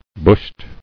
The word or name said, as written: Bushed